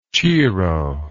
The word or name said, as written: cheero